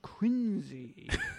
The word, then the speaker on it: Quincy: You read any biography about John Quincy Adams, and the first thing the biography is going to tell you is that it's pronounced Quincy. It's in all the biographies, every single one.